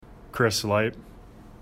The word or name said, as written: Lipe